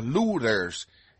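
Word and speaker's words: Luther: La familia de los Luthers. Esa es la pronunciación alemana de los Lutero, de donde viene el joven Martín Lutero.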